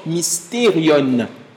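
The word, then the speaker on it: mysterion (μυστήριον): » Le mot mystère vient d'un mot, on l'a eu la semaine dernière, un mot grec qui se prononce mysterion, qui veut simplement dire secret.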